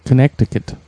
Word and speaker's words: Connecticut: It's not connect. The K in the middle is silent, isn't it? The C? – Connecticut. – It's Connecticut.